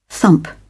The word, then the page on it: thump: British English